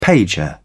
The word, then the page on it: pager: British English